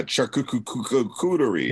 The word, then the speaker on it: charcuterie: Is that how you say it? Charcuterie? – Oh, yeah, charcuterie.